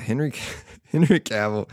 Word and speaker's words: Henry Cavill: So he said this in the first episode, and Henry Cavill does a great... I feel like I just mispronounced his name. Yeah, Henry Cavill.